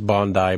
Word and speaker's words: Bondi: It says, at Tim Mitra, pedantic note, but it is pronounced Bond-I and not Bondi, I think, is what I've heard you use before.